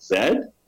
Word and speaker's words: Z: So, but our American friends, my American friends, of course, there's Z in Canada. We pronounce it Zed.